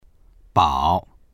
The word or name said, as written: bao3